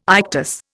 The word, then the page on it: ictus: Indian